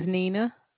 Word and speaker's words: Nina: My name is Nina. – Spell it for me. – N-I-N-A. – N-I-N-A.